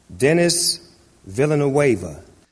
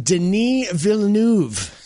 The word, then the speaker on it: Denis Villeneuve: Denis Villeneuve. – Yeah, got through that perfectly. Until you find out that he didn't. The guy's name is actually pronounced Denis Villeneuve. Snoop Dogg just botched that, too.